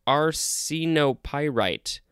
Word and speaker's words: arsenopyrite: Next, we have arsenopyrite. Arsenopyrite. That's how you pronounce it.